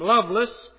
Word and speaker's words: Lovelace: Loveless, which is spelled L-O-V-E-L-A-C-E.